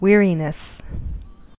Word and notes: weariness: American English

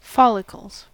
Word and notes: follicles: American English

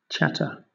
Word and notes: chatter: British English

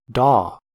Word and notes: daw: American English

